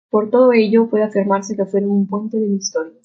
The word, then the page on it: afirmarse: ispanyolca